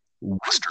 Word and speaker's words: Worcester: That city is pronounced Worcester. Worcester. Oh, damn it.